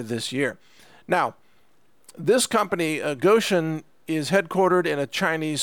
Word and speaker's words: Gotion: Gotion is spelled, it's just like the word motion, but a G instead of an M. Okay, so Gotion like motion.